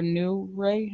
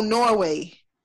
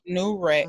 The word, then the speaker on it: Newry: That's how you pronounce it. – I didn't look. I didn't look at it yet. I haven't. I didn't go deep into like the cities and all that. I just looked at you know. – That's whatever. not Norway. This spelled N E W R Y. Oh. New Ray. Mm. – New Ray. It gotta be New Ray.